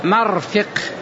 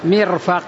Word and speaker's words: مرفق: كلاهما صحيح، أحدهما فتح الميم وكسر الفاء، مرفق، فتح الميم وكسر الفاء، والثاني كسر الميم وفتح الفاء، عكسها، مرفق ومرفق كلاهما صحيح، وجهان صحيحان، لغتان صحيحتان.